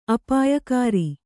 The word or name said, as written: ಅಪಾಯಕರ